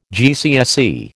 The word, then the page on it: GCSE: American English